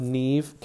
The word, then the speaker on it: Neve: Also, I didn't like the fact that I looked up Neve Campbell, and her name is pronounced Nev. Nev Campbell? It's Neve. It is Neve.